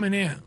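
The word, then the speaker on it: Manaea: Por cierto, el zurdo Sean Manea, ¿se pronuncia Manea? ¿Cómo es? – Sean Manea. – Manea. Manea. Okay.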